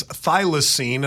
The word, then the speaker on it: thylacine: It has been decades since Australia's thylacine, and I said Tasmanian devil.